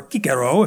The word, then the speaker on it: Cicero: Now, Cicero, or Cicero, if you want to pronounce it in the good Latin, was a statesman back in the century BC, a very, very influential speaker, author, whatever else.